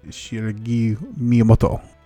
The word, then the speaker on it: Shigeru Miyamoto: I would like to go to the Japanese Nintendo World Headquarters and meet Shigeru Miyamoto.